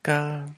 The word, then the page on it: kal: Lwaitel